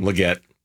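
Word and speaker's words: Legette: Did I pronounce the name wrong? – Leggett. – Okay, Xavier. Close enough. I went with the French version.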